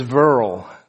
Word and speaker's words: Verle: So my first name is Verle. V-E-R-L-E.